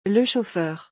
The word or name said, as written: le chauffeur